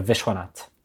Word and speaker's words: Vishwanath: We usually pronounce it by taking the H at the end and putting it after the S. So Vishwanath.